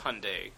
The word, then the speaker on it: Hyundai: First of all, it's Hyundai. – Hyundai, oh, sorry. – So if you could say that correctly from now on, that would be great.